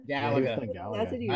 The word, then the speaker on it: Galaga: We talked about our computers, our first computers, but we didn't talk about what video games, because they had, is it pronounced Galaga? – Galaga. I love Galaga.